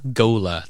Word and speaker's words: ghola: Gola. That's how I'm going to say it. It's spelled G-H-O-L-A. But I think so. I think it's supposed to be like ghoul with a golem. So Gola.